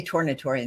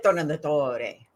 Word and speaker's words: Tornatore: In Italy, we say Tornatore in the United States, but I like Tornatore.